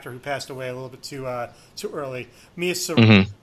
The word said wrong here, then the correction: Mia Sara: how do you say her name? – Sorvino? – No, S-A-R-A, Sierra? – Mia Sara.